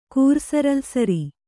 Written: ಕೂರ್ಸರಲ್ಸರಿ

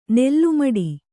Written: ನಾಲ್ಮಡಿ

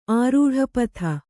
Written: ಆರೂಢಪಥ

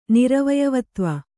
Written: ನಿರವಯವತ್ವ

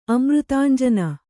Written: ಅಮೃತಾಂಜನ

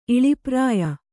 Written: ಇಳಿಪ್ರಾಯ

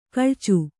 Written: ಕೊಳಚೆ

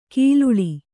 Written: ಕಲ್ಲಾಳ

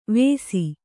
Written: ವೇಸಿ

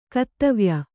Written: ಕತ್ತವ್ಯ

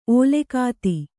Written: ಓಲೆಗಾತಿ

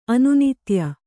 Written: ಅನುನಿತ್ಯ

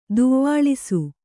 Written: ದುವ್ವಾಳಿಸು

